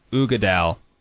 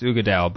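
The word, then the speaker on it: Uigeadail: But apparently it's pronounced Ugadal. – But or Ugadal. – Ugadal, Ugadal. I think it's Ugadal.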